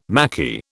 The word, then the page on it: Maquis: British English